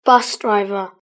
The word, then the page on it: bus driver: British English